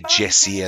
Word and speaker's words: JESSE: He is a producer of tunes, and he has been working on a 4-part mega concept album of 50 songs called Jess, J-E-S-S-E. And this is pronounced Jesse because, of course, J-C, you know, Jacob Collier, Jesse. That's where it comes from. – Oh, so, is that what it is? I thought it was Jess. – No, Jess. Because he puts it, you'll hear. – D-J-S.